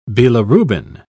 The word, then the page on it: bilirubin: British English